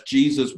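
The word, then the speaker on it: Jesús: Uh, that's Jesus with the accent on the U.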